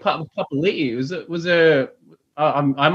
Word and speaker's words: Papali'i: Because he was always Papali, and then now they're both Papali. I must have missed that.